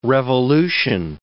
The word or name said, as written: revolution